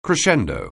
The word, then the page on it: crescendo: anglais